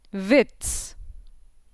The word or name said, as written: Witz